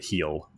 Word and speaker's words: Gil: Yes, that is Luis Gil, and that is G-I-L, if you are as painfully white as both Jordan and I. It is pronounced like your foot, heel.